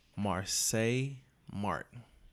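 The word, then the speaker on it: Marsai Martin: Marseille Martin.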